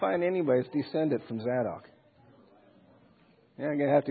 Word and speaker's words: Zadok: Sons of Zadok. I pronounce it Zadok, but who cares, you know.